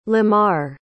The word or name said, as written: lemar